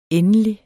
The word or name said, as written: endelig